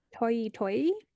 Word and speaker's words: Toyi Toyi: it's a Southern African dance called the Toyi Toyi. That's how you say it.